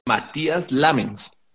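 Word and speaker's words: Matías Lammens: El tipo se llama Matías Lamens.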